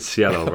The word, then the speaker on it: Cielo: Not quite, but... – how's it said? – The Cielo rather than the hard C.